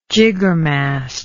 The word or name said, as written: jiggermast